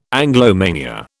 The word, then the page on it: Anglomania: British English